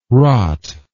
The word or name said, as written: rotte